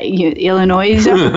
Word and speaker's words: Illinois: Yeah, Illinois. No noise in Illinois.